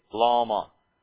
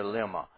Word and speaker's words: lama: Eli, Eli, lama, rather than here lima.